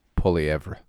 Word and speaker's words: Poilievre: Poilievre.